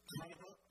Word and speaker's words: Kavanaugh: Kavanaugh.